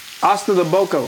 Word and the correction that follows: ossobuco: How you say it again? – Ossobuco. – Ossobuco.